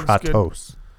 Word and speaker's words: Kratos: They made Kratos a real character. – It's actually pronounced Kratos.